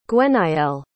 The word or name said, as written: gwenaëlle